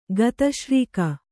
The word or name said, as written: ಗತಶ್ರೀಕ